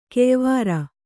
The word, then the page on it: ಕೆಯ್ವಾರಿ: keyvāri